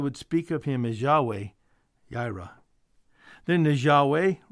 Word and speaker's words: Yahweh Jireh: You may hear this as Jehovah Jireh, but it's more probably pronounced in Hebrew, Yahweh Jireh, means the Lord will provide.